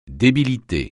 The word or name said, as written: débilité